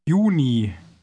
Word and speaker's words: Juni: Juni. Juni. – Note that German J is pronounced like an English Y, as in yes.